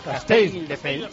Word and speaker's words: Castelldefels: Castelldefels.